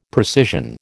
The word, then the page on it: precision: American English